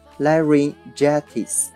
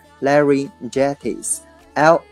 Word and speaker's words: laryngitis: OK，接下来呢一个单词叫做喉炎，它读作 laryngitis。 laryngitis，l a r y n g i t i s 啊，这个词呢也是名词啊，就是喉炎的意思。